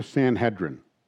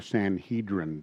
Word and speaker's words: Sanhedrin: The rulers and elders and scribes is speaking of the Sanhedrin, or the Sanhedrin, depending on how you want to pronounce it.